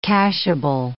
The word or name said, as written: cashable